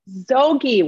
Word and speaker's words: Zoghi: Zoghi.